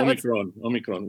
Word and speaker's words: Omicron: You got to roll the R. – Yeah. – Omicron, which means Omicron. The small. – The small. Yeah. Omicron.